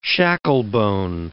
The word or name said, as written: shacklebone